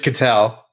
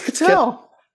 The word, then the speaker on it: Kittle: That's not how it is. – Isn't it just Kittle? – No, it's Kittle. – What? The first time I've ever heard Kittle, but I like it.